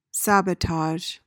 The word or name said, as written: sabotage